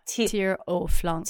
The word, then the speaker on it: Tire au flanc: Tire au flanc. You're not even going to say the C.